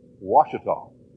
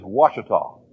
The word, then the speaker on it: Ouachita: Ouachita. It's like you take whatever tall might be and wash it. I don't know. It is Ouachita. And okay, I realize that though the Indians in Oklahoma spelled it with a W, I've never been able to figure out why. In Arkansas, they spell it with a W. We chuckle about this a little bit, but I do it intentionally. I hope you will hear very carefully. It is pronounced Ouachita,